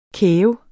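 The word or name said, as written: KÆV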